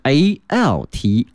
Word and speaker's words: altitude: 那么这个词啊和我们会的那个 attitude 态度啊有点相似哈啊，但是呢它的拼写里面还多少是不一样的，因为这个词呢后面是 altitude altitude altitude 哈，发音和这个 attitude 还是不一样的，所以同学们记下来 altitude 表示高度 about 1500 meters and various surfaces like rock, soil, clay and rubble。